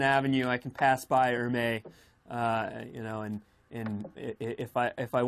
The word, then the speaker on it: Hermès: Dressed in Hermès was your favorite, was amazing.